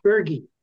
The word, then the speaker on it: Berge: So, Now there's one little tiny clarification, the pronunciation of my family name. It's Berge.